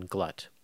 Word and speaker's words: Glut: So you'll hear in this episode, I erroneously say Don Glut, and it's Glut, just so you know.